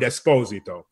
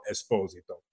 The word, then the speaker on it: Esposito: My complete name is Giancarlo Giuseppe Alessandro Esposito. In our country, America, we call it Esposito. – Right. – But it's really Esposito. So that's the New York pronunciation.